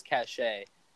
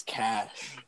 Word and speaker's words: cache: That is the right way to pronounce it. You see, I thought I was saying it wrong my whole life. I thought it was cachet. – Nah, bro, it's cash.